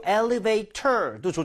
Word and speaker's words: elevator: So T가 모음 사이에 껴있으면 리을 비슷한 소리가 나게 됩니다. 그래서 그걸 flap이라고 부르는데요. So elevator도 좋지만 elevator 이렇게 부드럽게도 발음이 될 수 있어요.